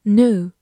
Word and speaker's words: new: e w 字母组合发 u，new，或者美式发音 e w 可以发 u，所以读成 new 也是可以的。